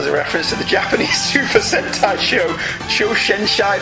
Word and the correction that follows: Choshinsei: Flashman, pronounced Choshinsei.